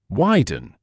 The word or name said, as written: widen